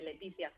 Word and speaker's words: Letizia: Se pronuncia exactamente igual que en español, Letizia.